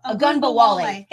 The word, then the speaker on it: Ogunbowale: I'm going to butcher his last name. Like, is it? Ogunbowale. I can't say his last name. – Ogunbowale. – Ogunbowale. Thank you.